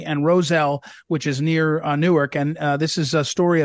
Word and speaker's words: Newark: And it's Newark, by the way, one syllable.